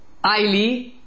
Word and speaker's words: Iole: En clase, o sea, perdón, en la sala de profesores, yo avisé, oye, mira, la chica que tiene el nombre I-O-L-E se pronuncia Ailey y no Iole, como dijo Gordon.